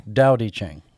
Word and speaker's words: Tao Te Ching: Tao Te Ching.